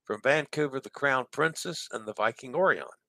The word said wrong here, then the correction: Orion: I said that wrong. Orion. Where'd that come from? Viking Orion.